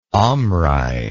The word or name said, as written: omri